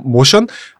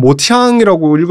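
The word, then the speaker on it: Motian: 그 시절이 이제 빌 에반스 가장 이제 유명하고 찬란한 시기로 사람들에게 이제 일컬어지는 시기인데 그게 베이시스트 스컷 라파로 드러머 톰 모션 폴 모션 아, 폴 모션, 음. 폴 아니면 이렇게 스펠상으로 는 모티앙이라고 읽을 수도 있는데 보통 폴 모션이라고 하죠.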